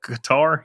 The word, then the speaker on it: Qatar: Qatar, yeah.